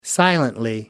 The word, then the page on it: silently: British English